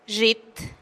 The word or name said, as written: zhit